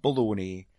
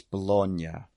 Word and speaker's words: Bologna: It's not Bologna. It's Bologna. It's not a meat.